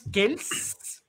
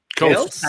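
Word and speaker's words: Kelce: Travis Kells, así dice que se, que se, que se, que se pronuncia. – Kells.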